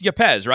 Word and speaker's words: Yepez: when you look at a guy like Yepez, it's Yepez, right? You say it with the emphasis on the second syllable, yep.